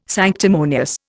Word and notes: sanctimonious: Indian

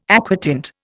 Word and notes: aquatint: Indian

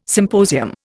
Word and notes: symposium: Indian

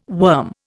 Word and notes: worm: Indian

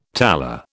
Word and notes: tala: British English

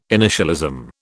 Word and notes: initialism: British English

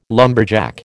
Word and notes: lumberjack: American English

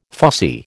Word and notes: fussy: American English

